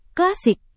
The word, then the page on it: klasik: Indonesian